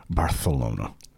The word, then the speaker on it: Barcelona: I've been to Barcelona, which is pronounced, by the way, Barcelona. – With a T? – Barth. Like you've got a lisp. Barcelona. – Oh. – That's how they pronounce it.